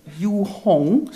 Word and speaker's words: Yu Hong: Yu Hong, zo moest je dat uitspreken, was Chinese.